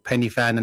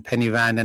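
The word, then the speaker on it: Pen y Fan: I think there was a whole round of Penny Fan and Penny Van and all sorts of various different pronunciation of it. – What is a pronunciation? – I've been told it's called Penny Fan.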